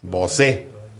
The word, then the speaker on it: Bosé: El nombre del artista lleva acento en la E. Bosé.